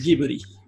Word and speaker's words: Ghibli: Ghibli, pues es un, es un compendio de, es una sílaba compuesta por dos consonantes y una, y una vocal. Y esto tampoco sucede en el japonés, entonces se dice como aquí nos mencionó Carlita.